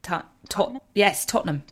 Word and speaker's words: Tottenham: If I say Tottenham? – Tottenham.